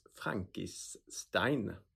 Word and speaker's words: Frankissstein: Idag hade Joel med sig Jeanette Wintersons Frankenstein. Nu låter det som att jag uttalar Frankenstein fel, men den heter så. – Ja, det är 3 S.